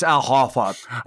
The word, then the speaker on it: Al Horford: You're mispronouncing it. It's Al Horford.